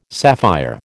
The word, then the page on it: sapphire: American English